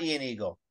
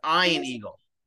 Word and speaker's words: Eagle: It's not Ian Eagle. It's Ian Eagle.